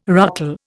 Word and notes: ruckle: Indian

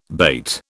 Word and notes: bate: British English

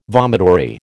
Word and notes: vomitory: American English

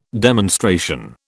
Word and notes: demonstration: British English

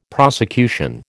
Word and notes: prosecution: American English

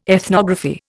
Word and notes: ethnography: Indian

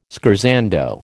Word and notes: scherzando: American English

